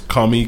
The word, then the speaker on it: Comey: It's kind of spelled like Comet. – Yeah, it's like Comet without the T. – With a Y. – Yeah, with a Y. So it's Comey, Comey.